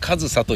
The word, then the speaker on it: 上総: 上層と書いて上総と読む